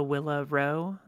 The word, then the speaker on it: Willa Rowe: Username is at the Willa Rowe.